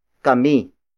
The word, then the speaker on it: kami: The next pronoun is kami. Kami. Kami. Kami is spelled as K-A-M-I.